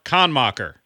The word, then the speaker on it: Kannmacher: Okay, we have Mark Kannmacher. Am I saying that right? – Yep, sure are. – It was like con soccer.